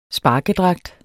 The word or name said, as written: sparkedragt